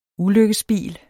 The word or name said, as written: ulykkesbil